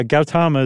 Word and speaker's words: Gautama: Yeah, Gautama is everything I saw.